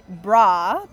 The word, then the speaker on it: brah: But yeah, it's going to be bra, but spelt with an H. – The B-R-A-H. New B-R-A-H. – Yeah. – Okay. – Bra. So like the bro, but the bra, but with the age.